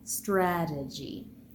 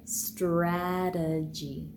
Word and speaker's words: strategy: Now in normal speech, because the second syllable is unstressed, that t will be transformed from a normal t to a tapped t. So we have strategy.